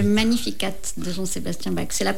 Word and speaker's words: Magnificat: Alors ça s'écrit Magnificat, mais il y a un T à la fin et on le prononce Magnificat de Jean-Sébastien Bach.